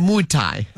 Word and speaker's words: Muay Thai: Muay Thai.